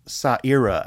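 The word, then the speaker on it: Ça Ira: C with the little hangy down dingly thing. – Okay. – Which makes it a soft C. – All right. – And an A, Sa. – Okay. – Ira, I-R-A.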